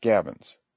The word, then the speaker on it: gabions: The word is pronounced gabions.